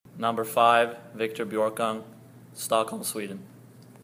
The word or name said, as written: Victor Björkung